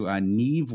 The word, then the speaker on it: Niamh: So we're going to go down to Niamh Wallace in seventh, Brendan. N-I-A-M-H.